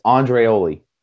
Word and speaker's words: Andreoli: It's Andreoli. That's how it's pronounced. I pronounced it wrong in the last one.